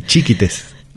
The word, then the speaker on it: chiquites: Con acento en la primera I, chiquites.